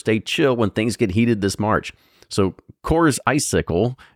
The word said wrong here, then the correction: Coorsicle: is intended for, Coorsicle. I was saying it wrong. It's Coors hyphen ICLE. So Coorsicle.